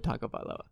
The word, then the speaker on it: Tagovailoa: That's just not going to happen unless somebody decides to shock the world and take Jordan Love over Tua Tagovailoa. Is that how you say his name? – Yeah, Tagovailoa.